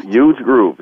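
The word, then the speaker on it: Euge Groove: So he's also garnered a Billboard Top 10 hit that featured smooth jazz superstar Huge Groove. And I hope I'm pronouncing that right. – That's so, right. Huge Groove. That's right.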